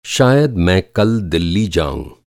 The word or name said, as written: दिल्ली